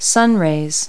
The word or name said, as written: sunrays